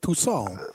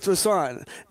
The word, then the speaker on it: Toussaint: Toussaint. – He sounds like a French, he does. – A French baguette, kind of, right? Toussaint. – I was thinking croissant, but okay.